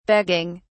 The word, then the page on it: begging: British English